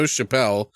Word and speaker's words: Chappelle: Director, and I'm pretty sure I'm saying this right, Joe Chappelle, sounds like Dave Chappelle, but that's what I thought of.